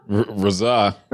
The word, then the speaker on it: RZA: Try his name. How do you say it? – RZA. – Yeah, RZA. So RZA spent some time.